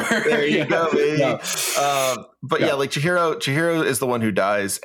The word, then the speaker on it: Chihiro: And did I say his name right? – Yeah, Chihiro. Okay. Yep, you got it.